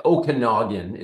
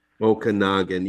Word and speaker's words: Okanagan: And you got it correct. Okanagan is how it's pronounced. – Okanagan.